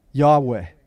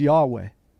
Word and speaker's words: Yahweh: More than likely, God's name is pronounced what? Yahweh. Yahweh. Yahweh. Something like that, right?